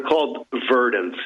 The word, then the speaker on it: Verdants: They're called Verdants, yes. – Spell it. – And there was actually V-E-R-D-A-N-T-S,